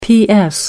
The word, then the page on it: ps: American English